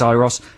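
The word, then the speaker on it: Euros: on whether to pronounce Uros Child's name Uros or Airos. – Airos, I think, is the... – We think it's Airos.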